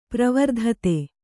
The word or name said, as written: ಪರಿವರ್ಧಿತ